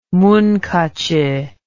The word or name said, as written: munkacsy